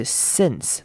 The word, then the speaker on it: since: Since then, since 要特别注意它的 I 是短母音 I 而不是 E 的声音，所以是 since 那个 in 的声音记得要念出来，不要念成 in.